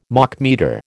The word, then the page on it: Machmeter: American English